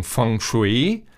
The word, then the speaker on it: Feng Shui: Der Begriff Feng Shui, ausgesprochen Feng Shui, bedeutet ja wörtlich übersetzt Weg des Windes und des Wassers.